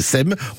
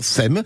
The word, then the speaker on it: SEPMES: Alors, pour ceux qui ne connaissent pas, ça s'écrit S-E-P-M-E-S, e e que certains prononcent SEPMES, mais non, c'est SEM. On dit bien SEM.